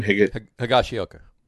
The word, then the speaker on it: Higashioka: Higashioka.